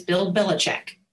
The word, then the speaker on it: Belichick: Oprah might lead to here is Bill Belichick. Can you believe I just pulled that out? I studied before. I cannot believe it. – Did I say his name right? – Yes.